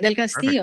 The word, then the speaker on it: del Castillo: Del Castillo.